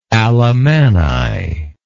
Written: Alamanni

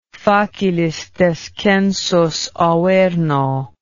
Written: facilis descensus averno

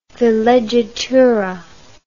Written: villeggiatura

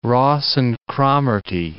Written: Ross and Cromarty

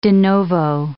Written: de novo

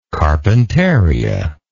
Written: carpentaria

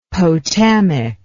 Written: potamic

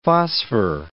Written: phosphor

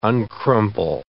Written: uncrumple

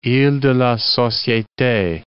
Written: iles de la Societe

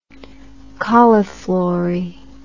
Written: cauliflory

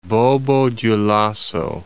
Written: Bobo-Dioulasso

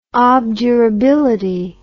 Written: obdurability

